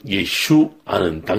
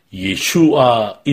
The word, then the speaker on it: 예슈아: 그런데 예슈아 이름에는 함정이 있습니다. 그것이 무엇이냐면 마치 수수께끼와도 같은 것입니다. 예슈아의 아를 길게 발음하면 하면 구원 혹은 구원자라는 뜻이 되지만 아를 짧게 발음하게 되면 아, 또 다른 의미를 갖습니다.